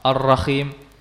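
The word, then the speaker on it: Ar-Rahim (الرحيم): Adapun huruf ha, nah ini huruf ha, tidak sampai rapat. Ya, perhatikan perbedaan antara keduanya. Coba ah. Ah. Ha. Nah, jadi begitu huruf kha, huruf ha, ya, tidak diucapkan dengan kha. Banyak dari kita yang mengucapkan huruf ha seperti huruf kha. Ar-Rahim. Ya, dia tidak mengeluarkan dari lisanul misr ini, tapi dia mengeluarkan dari makhrajnya huruf kha yang berada di sini.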